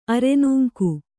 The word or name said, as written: ಅರೆನೂಂಕು